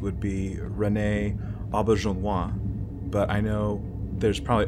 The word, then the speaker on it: Auberjonois: I feel like every time I've seen him talk about it, he pronounces it Auberjonois. – Okay. – So that's always the way I say it, is Auberjonois.